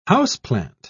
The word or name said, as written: houseplant